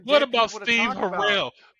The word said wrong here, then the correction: Harwell: How you say his name? – Harwell. – Harwell? – Yeah.